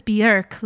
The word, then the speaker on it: Björk: Number two is going to be Yoga by Björk. And the only reason I say Björk is because in an interview, she will explain to you that she goes, "My name is Björk, like jerk."